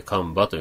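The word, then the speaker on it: 神庭: 神場の滝というのは、神の庭と書いて神場と読みますけどね。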